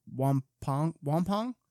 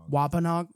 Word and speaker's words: Wampanoag: When 90, I'm going to butcher this, but Wampong and 50 Mayflower survivors basically had a long weekend together and ate the entire time. – Wampanoag.